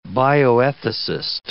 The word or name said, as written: bioethicist